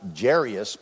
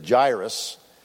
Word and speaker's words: Jairus: I used to pronounce it Jairus, but it is Jairus, who was a ruler of the synagogue.